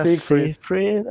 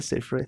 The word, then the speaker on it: Seyfried: Amanda Seyfried. – Amanda Seyfried.